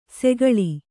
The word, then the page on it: ಸೆಗಳಿ: segaḷi